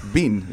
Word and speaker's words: been: And one of them, in an oddball category, was the word B-E-E-N. And my wife said, because it's pronounced bin. – Bin. – And it's not bin, it's bean. It's still bean.